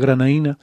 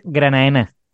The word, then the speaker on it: Granadina: Con nosotros se puso en contacto Sergio Alonso, que es de la Sociedad Astronómica Granadina, aunque se escribe Granadina, pero todos sabemos que se pronuncia Granaína, que están organizando... – En realidad se pronuncia Granaena.